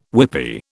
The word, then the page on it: whippy: British English